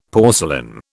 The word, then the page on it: porcelain: British English